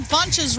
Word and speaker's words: Funches: Ron Funches rhymes with bunches.